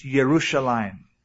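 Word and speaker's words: Yerushalayim (ירושלים): The Hebrews do not pronounce J. They don't say Jerusalem, it's Yerushalayim. And so Joshua was Yeshua.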